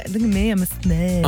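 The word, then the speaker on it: Ssaint: And Elle from Saint. And I say it like that because it's S-S-A-I-N-T. – Saint.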